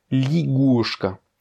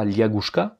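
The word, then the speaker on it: ligushka (лягушка): Ça s'écrit liagushka, mais ça se prononce ligushka. Parce que le ya n'est pas accentué, il va se prononcer comme un i. On ne dit donc pas liagushka, mais ligushka.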